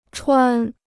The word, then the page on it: 穿: chuān